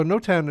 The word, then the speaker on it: Notan: and that is Notan study. I hope I'm saying that right. – Yes, you are. Notan studies, yes. – So Notan is basically you're looking at an artwork and you're looking at the light parts and the dark parts.